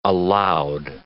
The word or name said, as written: aloud